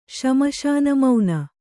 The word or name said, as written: ಶ್ಮಶಾನಮೌನ